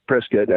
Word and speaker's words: Prescott: Prescott, I guess that's actually how you pronounce it, Prescott. That's how they say it around here.